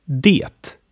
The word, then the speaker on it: det: Det. Das bedeutet das und wird oft wie D ausgesprochen.